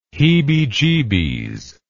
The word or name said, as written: heebiejeebies